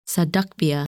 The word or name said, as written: Sadachbia